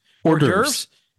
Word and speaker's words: hors d'oeuvres: Big man is going in big places and going to see all the whores divorce. Did I even say that right? – No. – Not even. How am I supposed to say that? Come on, tell me. Tell me. – Hors d'oeuvres.